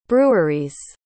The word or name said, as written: breweries